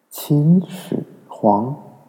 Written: Qin Shi Huang

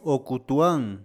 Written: Ocutuán

